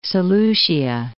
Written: Seleucia

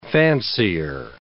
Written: fancier

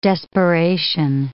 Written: desperation

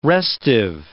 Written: restive

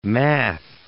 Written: math

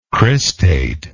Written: cristate

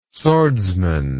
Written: swordsman

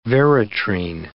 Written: veratrine

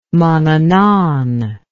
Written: manannan

